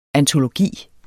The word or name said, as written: antologi